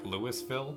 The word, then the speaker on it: Louisville: It's like an American city that's like Louisville or something, but it's like Louisville is how it's pronounced.